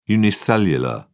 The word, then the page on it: unicellular: British English